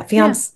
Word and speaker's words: fiancé: I just don't think you would have let your fiancés, is that how you say it? Yeah, fiance. Yeah.